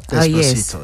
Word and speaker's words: Despacito: And, you know, he got this number one worldwide on iTunes with his song, I think it's pronounced Despacito. – Despacito, yes. Yeah.